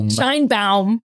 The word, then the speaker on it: Sheinbaum: So, Elena, you're going to say it right. It's going to be a Sheinbaum. Sheinbaum. We're going to do the Jewish way.